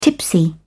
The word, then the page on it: tipsy: British English